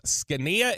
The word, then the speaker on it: Zgonina: RJ Choppy, the first one that we've hired, defensive line coach Jeff Scania. If you are going to Google that, good luck, because it is not the way that it is phonetically pronounced. Scania, Z-G-O-N-I-N-A.